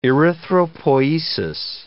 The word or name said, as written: erythropoiesis